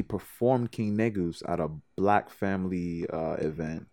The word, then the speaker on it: Negus: And they explained, there was a girl in the audience who was Ethiopian, and she told me the proper pronunciation would be Negus. But when I saw the word N-E-G-U-S,